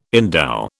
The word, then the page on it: endow: British English